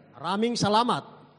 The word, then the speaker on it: Maraming salamat: How do you say? Pardon? – Maraming salamat. – Oh boy. – Maraming salamat. – Ramin salamat. It's good enough.